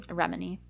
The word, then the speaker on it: Remini: How do you say her last name? – Remini. – Remini. – Is how I've...